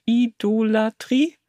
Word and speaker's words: Idolatrie: Apostasie und Idolatrie? – Richtig.